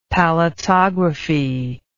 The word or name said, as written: palatography